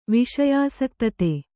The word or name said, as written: ವಿಷಯಾಸಕ್ತತೆ